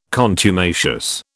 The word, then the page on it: contumacious: British English